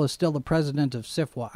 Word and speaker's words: SFWA: Dear Nebula Conference participants and CIFWA members, that's how it's pronounced. SFWA is pronounced. CIFWA is how they do it, is how they say it.